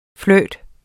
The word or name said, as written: flirt